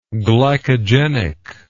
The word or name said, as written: glycogenic